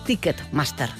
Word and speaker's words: Ticketmaster: Ticketmaster, ahora sí que lo he dicho bien.